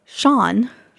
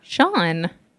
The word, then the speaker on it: Chan: Her name is Sean. – Sean. – But it's spelt C H A N.